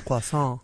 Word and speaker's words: croissant: They say you're supposed to pronounce it K-R-W-A-H. – Croissant. – Dash S-A-H-N. Croissant.